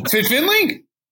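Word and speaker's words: Fit Finlay: His name rhymes with few Brackentire. – Whoa, Fit Finley? – Yes, exactly.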